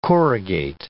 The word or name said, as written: corrugate